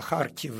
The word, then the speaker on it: Kharkiv: Da, la Kharkiv e un fel de h transliterat în engleză prin k și h, da, și g-ul care e transliterat prin h.